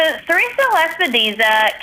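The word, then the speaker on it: sericea lespedeza: So our next topic here, and I'll let you pronounce it because, again, I Serratia Lepidesa. I can't control. I still think I butchered that. – Serratia Lepidesa.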